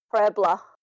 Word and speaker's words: Puebla: But it's Peubla, I think. – Puebla. – Was it? Is that how you said it? – Yeah, well, that's how I said it. But like I said, my pronunciation isn't the best. But that's what how I thought it was. – LA at the end.